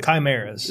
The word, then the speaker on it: chimeras: Is that right? – Chimeras. – Is that how you say it? – Yes.